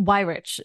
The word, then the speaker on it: Weyrich: It's Weirich.